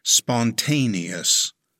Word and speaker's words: spontaneous: It has four syllables. Stress the second syllable. It's pronounced spontaneous.